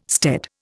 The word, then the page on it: stead: Indian